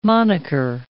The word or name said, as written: moniker